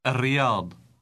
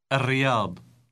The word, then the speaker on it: الرياض: Note how the L in the article is pronounced R. – الرياض. الرياض.